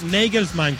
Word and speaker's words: Nagelsmann: Claro, es Nagelsmann.